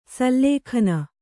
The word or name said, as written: ಸುಲಗ್ನ